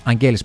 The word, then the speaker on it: Αγγέλης: ο ταξίαρχος Νικόλαος Αγγέλης είναι αυτός, Αγγέλης μάλλον το σωστό, εκεί τονίζεται,